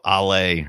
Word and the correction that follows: Ayla: Ayla, I think is her name.